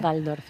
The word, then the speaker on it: Waldorf: He hablado con un amigo alemán y me ha dicho que se pronuncia Waldorf, aunque se escriba con W y nosotros tengamos esa tendencia. – No, es Waldorf, si es alemán es V, la W es V.